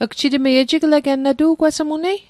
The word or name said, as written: ejigleget